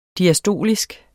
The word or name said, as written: diastolisk